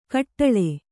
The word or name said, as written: ಖಟ್ಲೆ